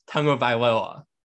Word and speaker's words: Tagovailoa: Let's see if I can pronounce his name on the first try. Tagovailoa. I think I got it there. – I think you did. Yeah.